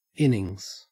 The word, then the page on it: innings: Australian English